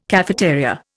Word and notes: cafeteria: Indian

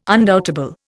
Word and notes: undoubtable: Indian

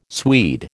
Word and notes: Swede: American English

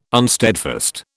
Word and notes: unsteadfast: British English

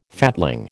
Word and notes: fatling: American English

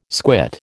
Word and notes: squit: American English

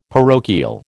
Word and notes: parochial: American English

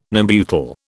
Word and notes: Nembutal: British English

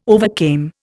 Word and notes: overcame: Indian

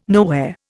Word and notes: nowhere: Indian

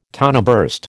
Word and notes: toneburst: American English

